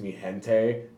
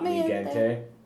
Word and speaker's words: Mi Gente: It's Mi Gente, not Mi Gente.